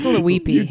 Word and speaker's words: Leuppi: Uh, the author is uh, a Dr. Jorg Lapui. I hope I am pronouncing that correct. no, at all. – I think it. it's Leuppi.